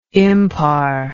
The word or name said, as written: impar